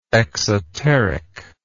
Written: exotericism

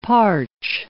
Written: parch

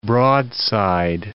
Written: broadside

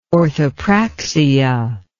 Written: orthopraxia

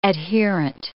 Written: adherent

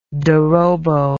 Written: dorobo

